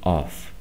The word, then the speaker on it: off: Si fuera el otro con doble F, ¿ok? Que es el de apagar, entonces ese sí se pronuncia con F. Off.